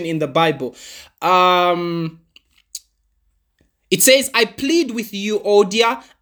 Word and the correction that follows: Euodia: and I plead with Syntyche. I can't remember how to pronounce that. I was listening to it the whole day on audio Bible, but I've forgotten the pronunciation. Euodia and Syntyche.